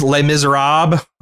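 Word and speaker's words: Les Misérables: Apparently it's Les Miserables. You don't do the last Les.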